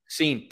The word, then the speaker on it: Cine: Moving on to the next player, and excuse me if I pronounce it wrong, Lewis Cine, safety. – Cine. – Damn, that's close.